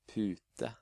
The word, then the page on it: pute: Norwegian